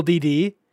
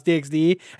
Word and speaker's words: DxD: him explain that it is pronounced DD whenever he was like, yes, I know some of you are going to comment some dumb shit like it's pronounced DxD. And he's like, but you're wrong.